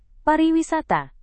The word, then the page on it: pariwisata: Indonesian